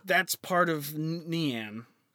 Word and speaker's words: Nian: And its name is Nian. N-I-A-N is how it's said.